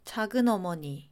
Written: 작은어머니

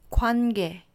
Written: 관계

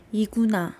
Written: iguna

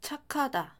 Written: 착하다

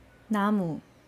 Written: namu

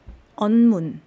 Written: eonmun

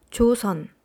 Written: josun

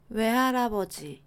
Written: 외할아버지

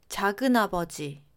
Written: 작은아버지

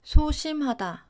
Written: 소심하다

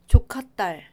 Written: 조카딸

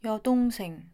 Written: 여동생

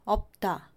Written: 없다

